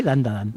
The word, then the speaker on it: Dandadan: Sí, Dandadan.